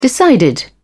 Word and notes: decided: British English

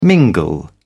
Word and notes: mingle: British English

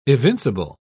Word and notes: evincible: British English

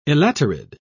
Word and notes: elaterid: British English

